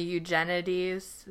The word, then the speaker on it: Eugenides: I'd recommend The Marriage Plot by Jeffrey Eugenides. Is that how you say it? – Yeah.